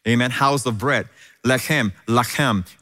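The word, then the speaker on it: lacham (לָחַם): And by the way, the word bread in Hebrew is lechem. And the word fight is lacham. Literally, from the same words, it's literally spelled the same way. Lechem, lacham. And it's just pronounced differently.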